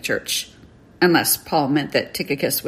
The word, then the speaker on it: Tychicus: On the other hand, the book ends with a mention of a fellow laborer with Paul named Tychicus, and you'll find quite a variety of pronunciations of his name. I'm sticking with Tychicus, where Paul specifically says that Tychicus will come to them and catch them up on how Paul is doing.